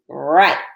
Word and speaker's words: write: T-W-O. Last one, number four. Let's write. Remember that W is silent. Let's trace the letters W-R-I-T-E. Let's write a story. And then let's write the word write on the line. W-R-I-T-E. Right.